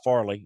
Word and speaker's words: Farley: Uh, Caleb Ferry of uh, Virginia Tech, uh, Farley is I think how you pronounce it actually.